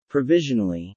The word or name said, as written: provisionally